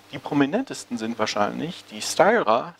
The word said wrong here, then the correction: Styria: Styra, Styra, ich möchte es nicht falsch aussprechen, jetzt habe ich es garantiert falsch ausgesprochen, die Styria, Styria Media Group, die praktisch vollständig in kirchlicher Hand liegt und zwei bedeutende Tageszeitungen herausgibt, die Kleine Zeitung und die Presse.